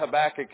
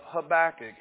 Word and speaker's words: Habakkuk: First of all, pronouncing the word Habakkuk is a victory in itself. I want to take you back to the book of Habakkuk.